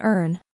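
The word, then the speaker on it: Erne: This is called an earn. It's actually pronounced like Ernie, and people may suspect you're a rookie if you don't pronounce it correctly.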